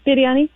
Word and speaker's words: biryani: Biryani. Okay. – Yeah, I didn't say it right. Say it again. – That's all right. Biryani.